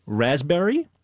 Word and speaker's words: raspberry: K-N-E-E-L, Neil. – Raspberry answer. – Silent right. letters. – Yes, silent letters. Right. Raspberry is? – The silent S. – P. – Or P. Sorry. – Silent P, right.